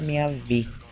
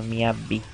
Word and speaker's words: MIYAVI: A banda acabou em 2002 e o Miave, então Miabi, passou para a carreira solo e mudou seu nome para Miavi, porque a sílaba Vi e B em japonês é praticamente a mesma, porque os japoneses têm um problema sério, o problema é ouvir, tanto que você escuta eles falando Miabi todo o tempo.